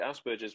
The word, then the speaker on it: Asperger's: I say Asperger's because I'm British. So that's how we say it. – That's just how you say it. Oh, right, it's Asperger. Yeah, but Asperger is because the guy, it comes from a gentleman called Hans Asperger, who was doing information and research on the behavior of children in the 1940s.